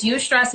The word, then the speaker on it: eustress: And there's you stress and this stress, right? So you stress is spelled E-U-S-T-R-E-S-S, right?